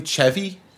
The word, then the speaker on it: Chevy: Wait, is his name Chevy? – Yeah, Chevy. – Chevy. – Chevy. – Chevy. Chevy. Chevy. It's like the car, Chevy.